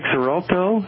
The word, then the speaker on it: Xarelto: I take Xarelto.